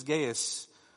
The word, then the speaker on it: Gaius: Latin is Gaius, and English is Gaius. So in case you think that I'm mispronouncing it, you can think of it as the Latin pronunciation of this individual.